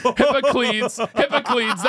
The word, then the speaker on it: Hippocleides: Hippocleides. That's how it's pronounced.